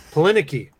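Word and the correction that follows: Adrianne Palicki: Adrian Palicki.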